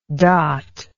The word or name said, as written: dotlike